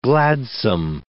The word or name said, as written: gladsome